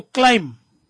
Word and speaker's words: climb: And, kona mvona neva kukupahama is to climb. The B is silent.